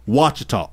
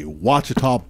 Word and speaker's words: Ouachita: Is it Ochita Baptist? – Wachita. – Spell it. – O-U-A. – Oh, so it is. O-U-A-C-H-I-T-A. – Wachita Baptist.